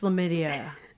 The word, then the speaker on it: Lammidia: If you haven't seen it, it's Lamidia. Rhymes with chlamydia.